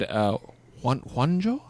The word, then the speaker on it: Juanjo: And Juanjo. – Like one ho.